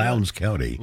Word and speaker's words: Lowndes: Lowndes? Is that how you say it? – Lowndes County. – Lowndes County. Yeah.